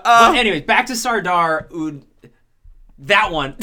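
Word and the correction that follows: Sardar Udham: Sardar Udom.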